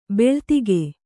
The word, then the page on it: ಭಲ್ಲಾತಕಿ: bhallātaki